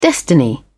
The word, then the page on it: destiny: British English